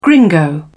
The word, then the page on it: gringo: British English